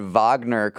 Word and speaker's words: Wagner: I said Wagner correctly when talking about Russia's mercenary army. So that's pretty awesome. If you are talking about that story with any of your friends, you can pronounce it the right way.